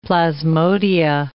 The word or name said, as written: plasmodia